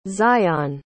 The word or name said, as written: zion